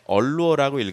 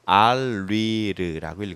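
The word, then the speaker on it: Allure: 근데 이거는 프랑스 이름이기 때문에 얼루어라고 읽으면 안 되고 알리르라고 읽어야 돼요.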